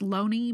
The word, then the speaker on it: Looney: It's apparently pronounced Loney, but it's spelled Looney, Looney.